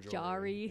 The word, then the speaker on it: Jory: I've got Corey, I've got Rory, I've got everything you could think of other than Jory.